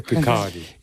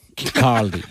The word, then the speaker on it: Kirkcaldy: Se pronuncia Kirkcaldy. – Kirkcaldy.